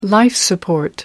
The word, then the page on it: life-support: British English